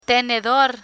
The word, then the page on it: tenedor: spanish